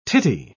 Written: tittie

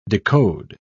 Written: decode

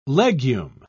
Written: legume

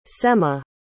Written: semah

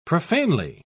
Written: profanely

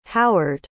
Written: howard

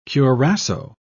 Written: curassow

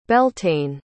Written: beltane

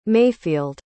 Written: mayfield

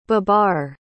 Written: babar

